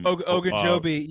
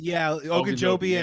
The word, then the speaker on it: Ogunjobi: Ogunjobi. Ogunjobi.